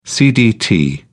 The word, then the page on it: cdt: British English